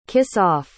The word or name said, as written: kiss-off